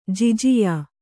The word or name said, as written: ಜಿಜಿಯಾ